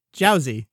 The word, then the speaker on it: Jiaozi: So the character is named after these pot sticker dumplings, which in Chinese, they're called Jiaozi. Get into the pronunciation spelling later, but in Japanese these are gyoza. And so in both languages they're written with the same characters, but they just have different readings between the languages.